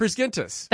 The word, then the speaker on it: Prisgintis: Oh, did I say it right? I said it right the other day. It's a hard G. – Yes. – Prisgintis.